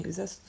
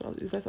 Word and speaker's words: Uzès: ich durfte mir das, halt immer dann im Urlaub so, es gibt es in, äh, in Uzès, ist das, oder Uzès? Nee, Uzès, Uzès ausgesprochen.